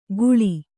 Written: ಘೂಳಿ

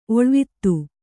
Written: ಒಳುವಾತು

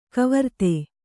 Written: ಕವರ್ತೆ

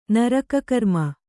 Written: ನರಕಕರ್ಮ

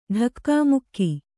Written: ಢಕ್ಕಾಮುಕ್ಕಿ

